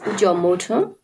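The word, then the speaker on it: Kyaw Moe Tun: Within the context of these atrocities, a speech was made at the UN by a man by the name of Jomo Tun. Is that how I pronounce it? – Yes, Jomo Tun.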